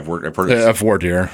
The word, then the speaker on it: voir dire: Do we say voir dire, voir dire? – Voir dire. – Yeah, that's how I pronounced it.